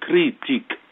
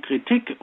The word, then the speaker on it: Kritik: Jahrhunderts, ähm, hat es geprägt, äh, Richard Simon, und äh, das Wort Kritik, das wir im Deutschen ja auf der zweiten Silbe betonen, obwohl das Deutsche sonst immer die erste Silbe betont. Nur die Schweizer sagen Kritik. Aber wir sagen Kritik und das ist eben das französische Wort, das wir übernommen haben.